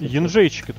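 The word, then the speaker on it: Jędrzejczyk: Вот эта вот женщина с невыговариваемой фамилией, вот этот же Дрейчик, Вот Янжейчик, это правильно читается.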